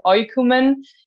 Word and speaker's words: Oïkoumen: Yes, I don't have the word for this character, but this is the double dot on the I, because in French, when you have an O and an I, it's wa, and Oïkoumen is not the right way to pronounce the name of our band. So that's why there is this double dot for Oïkoumen.